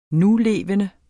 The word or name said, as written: nulevende